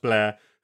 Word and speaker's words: Bler: And there have been one or two people, in fact, I think he's just called Blair, B-L-E-R, but pronounced Blair, who became a footballer, you know, fairly nationally recognised guy in Kosovo.